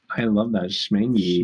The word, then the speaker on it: Schmengie: Schmengie, Correct. Schmengie, Schmengie. It's spelled Schmengie. H M E N G I E. It has a C in it.